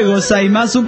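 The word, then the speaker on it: gozaimas (ございます): Y muchas gracias es domo arigato gasimasu. – Domo arigato gasimasu. – Maso maso, ¿no? Domo arigato gozaimas. – Domo arigato gozaimas. – Pues se escribe gozaimasu, pero es gozaimas.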